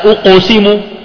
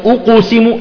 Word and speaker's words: أقسم: أنا بنبه الطلاب له مباشرة عندما تنطق بالضمة في أو في قبلها قاف، مباشرة أرجع شفتيك مباشرة، يعني تقول لا أقسم أقا أقا ما أقو، إذن أقسم أو أقسم، لا إذن أقسم، ترجع بالشفايف.